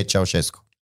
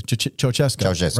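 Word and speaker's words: Ceaușescu: Nicolae Ceausescu. – Okay. Ceausescu.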